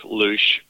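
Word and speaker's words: Luszcz: And here to tell us about it is Derek Lusch, and I am co-owner of Jean's Sausage Shop.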